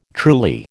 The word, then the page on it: truly: American English